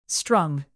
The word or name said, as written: strung